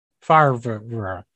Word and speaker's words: Favre: it's like Brett Favre. Favre. – Yes, the French pronunciation of his name.